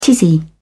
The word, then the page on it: tizzy: British English